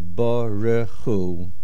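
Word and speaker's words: Baruch Hu (ברכו): Now, the correct way to say the word is Baruch Hu.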